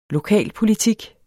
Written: lokalpolitik

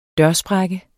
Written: dørsprække

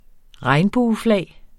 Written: regnbueflag

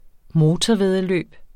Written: motorvæddeløb

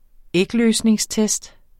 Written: ægløsningstest

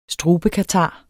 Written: strubekatar

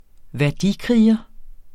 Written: værdikriger

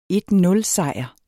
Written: 1-0-sejr

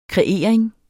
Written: kreering

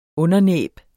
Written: undernæb

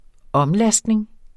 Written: omlastning

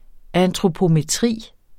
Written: antropometri

